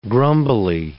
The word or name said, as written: grumbly